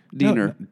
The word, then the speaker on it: Diener: I think it's D-I-E-N-E-R. No, D-I-E-N-R. – D-E-I. – Oh, I got it wrong. – N-E-R. Diner. Diener.